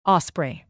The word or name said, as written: osprey